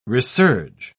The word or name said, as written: resurge